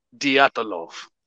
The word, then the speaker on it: Dyatlov: how do you say it? – Dyatlov. Dyatlov. Dyatlov Pass. Dyatlov. – Dyatlov. Dyatlov. Okay.